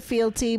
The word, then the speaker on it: fealty: I just said that I did pronounce it felty and they said fealty, but you know, nobody uses this word anymore.